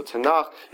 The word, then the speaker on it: Tanakh: So Tanakh, you can see here, that's how it's spelled, although sometimes instead of a K-H, it's spelled with a C-H. And that is kind of a curiosity of the Hebrew language. So the Hebrew language has a really wonderful sound, which is the ch sound.